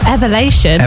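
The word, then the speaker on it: Elevation: So that's Praise by is it Evolution Worship? Is that how you say it? – It's Evelation.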